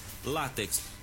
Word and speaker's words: látex: No entanto, é muito comum o uso na linguagem popular em dizer látex, mas na verdade a palavra correta é paroxítona, sílaba tônica no lá, látex.